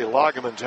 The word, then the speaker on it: Lagerman: On the arc for New Knoxville, it's Caleb Lagerman who's in, or I should say Lagerman's how it's pronounced, a 5'10 junior guard.